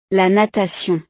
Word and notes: la natation: frances

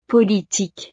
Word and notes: politique: frances

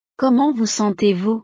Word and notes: comment vous sentezvous: frances

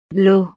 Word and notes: l'eau: frances

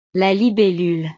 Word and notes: la libellule: French

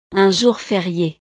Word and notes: un jour ferie: frances

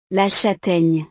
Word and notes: la chataigne: frances

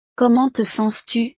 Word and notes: comment te sens tu: frances

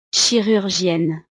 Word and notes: chirurgienne: frances

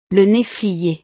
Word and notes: la neflier: frances

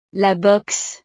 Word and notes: la boxe: frances

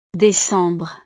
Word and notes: decembre: frances

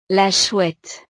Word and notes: la chouette: frances

